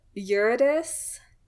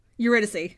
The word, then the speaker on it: Eurydice: So he, in probably his like most famous story, he chased, why didn't I look up any of the pronunciations? Eurydice? – Eurydice. – Eurydice. Yeah, I was like, that's not quite right.